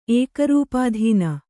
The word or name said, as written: ಏಕರೂಪಾಧೀನ